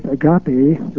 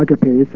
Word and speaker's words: agape: And then there is a third kind of love, which biblical scholars will be familiar with, agape, or agape, sometimes pronounced, which is the pure form generous love that wants nothing in return, that seeks absolutely no return whatsoever.